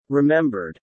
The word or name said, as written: remembered